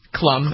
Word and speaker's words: Klum: Heidi Klum. Sofia Vergara is there. Howie Mandel is there. – Yeah. – And it's Heidi Klum. – What did I say? – Klum. It's Klum. Yeah. It's Klum.